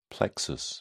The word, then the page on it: plexus: British English